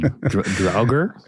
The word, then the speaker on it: Draugr: It's D-R-A-U-G-R.